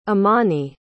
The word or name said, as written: emani